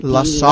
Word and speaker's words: La Salle: La Salle.